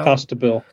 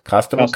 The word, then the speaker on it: Costabile: How do you pronounce his name? Costabile? Costabile? – Costabile. – Costabile. Costabile, of course.